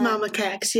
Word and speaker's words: Mama Cax: It was Chelsea Werner, Jillian Mercado, and how do you pronounce her name? Mama Cax? – I think it is Mama Cax, yes.